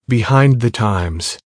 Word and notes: behind the times: American English

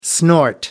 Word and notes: snort: American English

